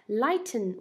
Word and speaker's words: lighten: 这个发音注意一下 ,lighten, 我们很多时候美音当中直接把 ten 给吃进去了啊，所以变成了 lighten 啊。